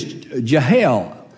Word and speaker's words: Jael: As a matter of fact, there's a woman in the book of Judges, several graphic scenes there, but her name is Jael. I think that's the correct pronunciation, J-A-E-L.